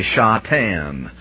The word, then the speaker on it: Shatan: The word for Satan in Hebrew is Satan, pronounced a little bit differently, Shatan.